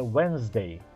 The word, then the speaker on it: Wednesday: Então, vamos entender primeiramente que, embora quarta-feira seja escrito Wednesday, Wednesday, a pronúncia correta é Wednesday, Wednesday. Então, imagine que você corta a letra D fora. Pronto, você já tem a pronúncia correta aí da palavra quarta-feira em inglês, Wednesday. Não tente falar o D, ele não é pronunciado.